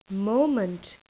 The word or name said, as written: moment